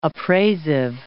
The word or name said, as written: appraisive